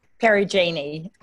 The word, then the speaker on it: Perugini: Perugini.